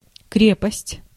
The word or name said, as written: strength